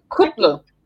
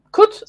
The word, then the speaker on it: Kutlu: Kutlu. Kutlu.